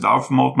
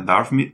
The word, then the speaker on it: Dartmouth: Escreve-se Dartmouth, mas na pronúncia da região ali na Inglaterra Dartmouth.